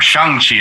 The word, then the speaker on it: Shang-Chi: Like sometimes, like, oh, that's Simu Liu, but then there were points like, he's Shang-Chi, or Shang-Chi as it's pronounced in the movie.